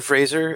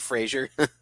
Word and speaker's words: Fraser: Yeah, because most people say Fraser. – Fucking, it's Fraser.